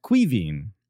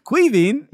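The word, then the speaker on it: Caoimhín: Koyman. Something like Koyan. – It's pronounced Kuivin. – Kuivin. – I'm not making a joke. That is how it's.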